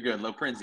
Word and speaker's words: Loprinzi: Brad, I pronounced your last name correctly. Is that right? – Yeah, it's you're good. Loprinzi, the Italian way.